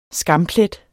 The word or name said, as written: SKAMPLETTER